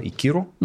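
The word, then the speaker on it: Ikiru: А, един от най тежичките за гледане поради дължина, сюжет и така нататък филми на Кира Коросава, сказва и Киро, mm-hmm. това ли е правилното ударение? Да, да.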